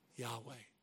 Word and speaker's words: Yahweh: That's been transliterated into the English as Jehovah. But scholars today believe a better pronunciation of that name is Yahweh.